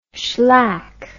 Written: schlack

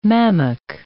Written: mammock

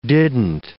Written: didn't

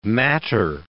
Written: matter

